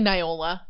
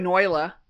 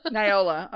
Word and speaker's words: Neola: How do you say that name again? – It's really Neola. Neola. – Okay. I was going Noyla. – Noyla. Oh, no, the I goes first. – Neola.